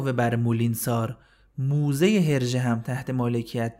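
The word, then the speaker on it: Moulinsart: مولینسار هم تلفظ فرانسوی این کلمه هست و نوشتنش یه ذره فرق داره و نوشته میشه مولینزارت یعنی آخرش کلمه آرت رو داره مولینز آرت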